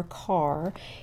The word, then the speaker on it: Kerr: Here in North Carolina, we would say Carr, K-E-R-R is pronounced Carr, but I know elsewhere it's pronounced Kerr. In fact, I know Bill Kerr pronounces his name Kerr, but he has a cousin in Durham, so he knows that we here say Carr.